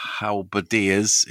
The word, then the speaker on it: halberdiers: I've got a half-finished unit of halberdiers, is the correct pronunciation.